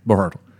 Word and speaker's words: Berhalter: Greg Berhalter. Yeah, Berhalter.